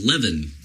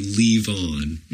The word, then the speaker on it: Levon: Which, actually, when I read it out loud to myself, I was like, oh, Levin, you know? – Levin. – But then I remember, that's not how he actually pronounces it. You know, it's got to be Leave On.